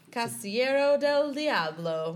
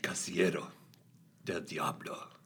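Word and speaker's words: Casillero del Diablo: Casiero del Diablo. – That's exactly how you say it. – How are you going to say it? – Casiero del Diablo.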